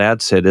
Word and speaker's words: Adsit: Scott adds it. I don't know why I pronounced his name wrong. I was about to say like he's a friend of mine, and then I mispronounced his name, and it sounds like maybe he's not.